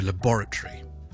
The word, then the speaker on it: laboratory: 例子，最簡單的例子，實驗室，我們英式英文是 laboratory laboratory，今天的重音就是在第二個音節的 border， laboratory laboratory。